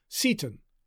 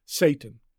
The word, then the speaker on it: Seyton: The pronunciation guides tell us that the name is probably to be pronounced Seton. But Shakespeare must have intended the audience to hear it as very close to, if not identical with, Satan.